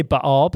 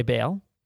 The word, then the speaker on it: Baal: The whole thing with, how do you say it? Baal, Baal? – I say Baal, but you could say Baal.